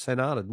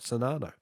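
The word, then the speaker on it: St. Arnaud: Bill from Ballarat says, by the way, St. Arnold is pronounced St. Arnold, not Sonano.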